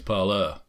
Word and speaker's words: Parler: It's not Parler, it's Parler. – Well, why spell it as a French word and not pronounce it as a French word?